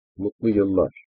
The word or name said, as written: mutlu yillar